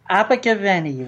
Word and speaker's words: Abergavenny: He was born in the United Kingdom in the South Wales market town of Abergavenny, how do you say that? – Abergavenny, Rob.